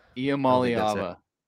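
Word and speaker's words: Iamaleava: Iamaliava.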